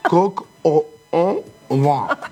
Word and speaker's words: coq au vin: Y después el otro es el coq au vin. Ah, yo dije vin, ¿viste? ¿Viste lo que es el tipo que sabe? Coq au vin.